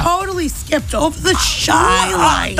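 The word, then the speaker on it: Chi-Lites: Shy Lights. C-H-I, youngin', as in Chicago, obviously.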